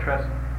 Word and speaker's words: trestle: They call it trestle. – Oh yeah. – What's that? You say. – Trestle. – Yeah. – Is that the way it's pronounced? – Yeah, that's exactly it.